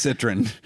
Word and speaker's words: Citroën: It's Citroën.